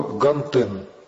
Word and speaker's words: Гантен: Вот, он был выходцем из Бенина, и зовут его Гантен. Ну, я ударение ставлю по-французски на последний слог.